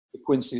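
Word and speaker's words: De Quincey: And De Quincy is spelled DEQUINCEY.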